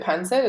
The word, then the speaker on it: pancit: And the first time I tried, you pronounce it pancit, is that how you do it? – Yeah.